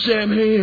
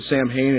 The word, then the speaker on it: Samhain: And, all of a sudden, I'm like, you guys realize that, you don't really pronounce it Sam Hain, it's actually pronounced Samhain.